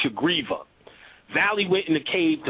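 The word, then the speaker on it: Sugriva: Yes, Hanuman, but Rama was teaching Shagriva, actually, is the pronunciation.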